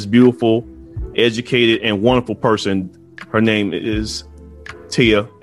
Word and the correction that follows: Ty: Abrams. Did I say that right? – Ty Abrams. – Like Thai food, the tie that you wear, all of those things. – Okay, cool. Ty Abrams.